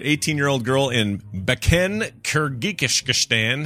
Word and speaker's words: Kyrgyzstan: Kyrgyzstan. – Oh, there we go. Let's hear that again. – Kyrgyzstan.